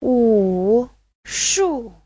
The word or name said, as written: wu3shu4